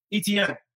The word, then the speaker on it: Etienne: How do you say his name, that guy? – ETN.